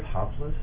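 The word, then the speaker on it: Hop-loose (ἁπλοῦς): Hop-loose. Hop-loose. – Hi. – P sound. L Lamp sound. Oos. The O-U is oo. Sigma S. – So what's the definition of that? That's a real word? Hop-loose? – Yeah.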